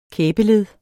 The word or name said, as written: kæbeled